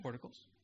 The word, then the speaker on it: porticos: How do you say that? – Porticos.